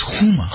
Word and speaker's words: Huma: Do you pronounce the H? I think you do. In a Farsi, you do. Like Huma. Her name is Huma. Huma. You have to say it at the back of your throat.